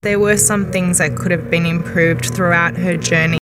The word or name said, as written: could have been